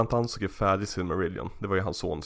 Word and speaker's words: Silmarillion: Men det här eh, similar, vad heter, vad heter den? Similar, jag kan inte uttala det. – Silmarillion. Ja.